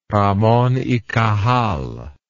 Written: Ramon y Cajal

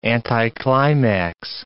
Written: anticlimax

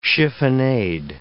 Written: chiffonade

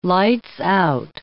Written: lights-out

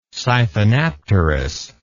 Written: siphonapterous

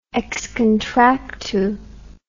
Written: ex contractu

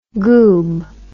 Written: goob

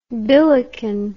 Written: billiken

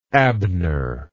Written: abner

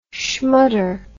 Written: schmutter

